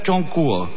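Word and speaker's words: Chongkuo (中国): China en chino se escribe Pinyin y agrega entre paréntesis Songwo y en caracteres se debe pronunciar Chongkuo.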